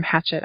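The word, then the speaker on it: Hachette: It's from Hatchet Audio. And it was it's a hatchet. – Hatchet. – Not hatchet. – Well. – Hatchet. Should be hatchet. It's French. Not hatchet.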